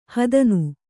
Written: ಹೆದ್ನ

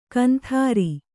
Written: ಕುಂದ್ರಿ

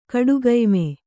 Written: ಕಡುಗಯ್ಮೆ

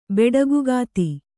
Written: ಬೆಡಗುಗಾತಿ

